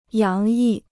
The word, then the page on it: 洋溢: yáng yì